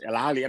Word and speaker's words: Al Ahly: Is it Al Ahly or Al Ahly? – Yes, Al Ahly. I mean, the H is...